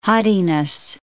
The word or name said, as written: haughtiness